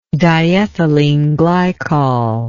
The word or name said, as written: diethyleneglycol